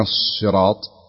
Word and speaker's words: الصراط: الصاد والسين طبعا مع اختلاف الروايات، لكن رواية حفص الصاد تنطق اهدنا الصراط، يكون في تفخيم الصاد، يعني يمتلئ الحرف أو الفم بنطق بالحرف.